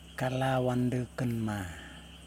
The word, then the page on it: kalawandukinma: Yokoim